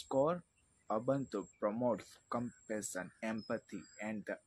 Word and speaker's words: Ubuntu: Ubuntu, pronounced Ubuntu, is a philosophy that reminds us of our interconnectedness and shared humanity.